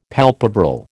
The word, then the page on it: palpebral: American English